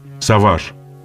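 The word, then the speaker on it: Savage: В России того времени было принято произносить название на французский манер «Саваж».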